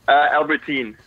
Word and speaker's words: Albertyn: Albertyn.